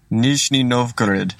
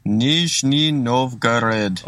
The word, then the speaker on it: Nizhny Novgorod: Nizhny Novgorod. – Okay, in Nizhny Novgorod. Is that right? – Nizhny Novgorod. – All right. Doesn't he sound a bit pissed off there? He's like, "It's Nizhny Novgorod, for goodness sake."